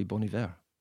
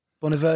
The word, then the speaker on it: Bon Iver: Bon Iver first. In fact, now I'm saying that, I keep thinking, that's the wrong pronunciation, isn't it? I think it's supposed to be Bon Iver. – Bon Iver, yeah, my Alexa said that this morning.